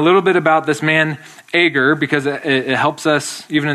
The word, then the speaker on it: Agur: And tonight we have a prayer from a man named Agur. That's how I pronounce it, A-G-U-R, Agur, from Proverbs 30.